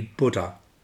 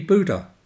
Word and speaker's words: Buddha: Now, here we come to the first transatlantic problem, because I come from Britain, and in Britain, and certainly in Australia, the word would be Buddha, because that's how we pronounce the letter U. In much of North America, it would be Buddha, because it's the nuclear debate all over again.